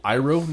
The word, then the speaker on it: Iroh: I went with Uncle Iroh next.